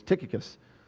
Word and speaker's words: Tychicus: We could call him Tychicus, but then he would come in here from the dead and kill us because we're saying his name wrong. It's Tychicus. And here's what Paul says.